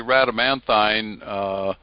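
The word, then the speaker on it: Rhadamanthine: It's well, we talked about the fact that, you know, we're supposed to be Radamanthine. Some people pronounce it Radamanthin, but either way is acceptable.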